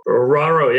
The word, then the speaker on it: Roero: Roero, yeah, that's uh, that's close enough.